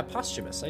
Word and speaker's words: posthumous: Oh yeah, posthumous. I did pronounce it correctly accidentally.